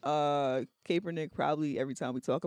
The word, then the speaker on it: Kaepernick: Parentheses, his name is pronounced Kaepernick, not Kaepernick.